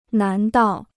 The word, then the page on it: 难道: nán dào